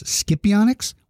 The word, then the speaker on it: Scipionyx: Now, the name is very confusing because it's spelled S-C-I-P-I-O-N-Y-X. And you'd think, well, how do you pronounce it? Scipionyx? Scipionyx? Well, it's pronounced Scipionyx. It's not pronounced, the C is not hard. It's pronounced like an H. It's Scipionyx. Its name is Scipionyx semnicticus.